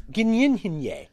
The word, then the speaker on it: Gyllenhaal: He says, "It's Dutch." And so the correct pronunciation is Gyllenhaal.